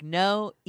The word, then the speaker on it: Noe: Yeah, you did. Noe. Yes. Like, Noe.